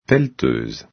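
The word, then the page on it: pelleteuse: Quebec French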